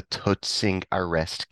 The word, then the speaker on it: Toetsingsarrest: Can you tell us about the Toetsingsarrest case? – Yes, thank you, thank you. – Nice Dutch uh, pronunciation.